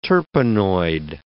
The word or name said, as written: terpenoid